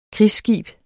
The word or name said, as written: krigsskib